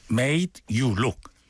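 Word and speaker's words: Made You Look: 발음 이상했는데. 메이드 유 룩. 빨리 하면 메이드 유 룩.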